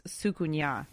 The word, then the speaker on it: soucouyant: And so it's spelled S-O-U-C-O-U-Y-A-N-T. But mm-hmm. from what I found online, it's pronounced sukunya.